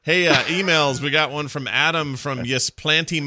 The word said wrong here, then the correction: Ypsilanti: Michigan, or how do you say it? YP. – Oh, Ypsilanti. – Ypsilanti. Is that how you say it? – Okay.